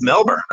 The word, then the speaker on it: Melbourne: It's Melbourne, right? It's just Melbourne. – Right? Nailed it.